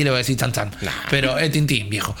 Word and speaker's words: Tintín: Tiene, lo tiene muy metido dentro de la propia cultura porque es muy común que eh, los niños crezcan leyendo Tintín, que se pronuncia tan tan.